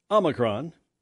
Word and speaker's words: Omicron: The group says the variant, Omicron, should be pronounced Omicron. I think some people are saying Omicron, but that's about it.